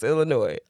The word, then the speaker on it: Illinois: Ain't no fucking S when you pronounce Illinois.